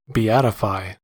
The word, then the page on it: beatify: American English